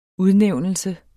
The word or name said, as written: udnævnelse